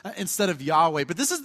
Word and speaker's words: Yahweh: Actually, we don't really know how to say it because it's four consonant sounds in the Hebrew. They don't have vowel sounds. We got to kind of provide them for those words. So Yahweh is kind of the way that we've said it.